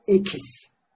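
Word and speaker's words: equis: O konuşuda ekis. Ekis.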